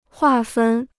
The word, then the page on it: 划分: huà fēn